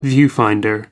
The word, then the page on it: viewfinder: American English